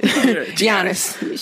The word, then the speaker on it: Giannis: Giannis, sorry, I pronounced the G and shit. Giannis, shit, whatever. I was close.